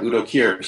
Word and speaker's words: Udo Kier: Yeah, Udo Kier, star of Paul Morrissey, Andy Warhol's Frankenstein.